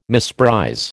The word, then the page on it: misprize: American English